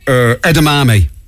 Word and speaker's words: edamame: Edamame.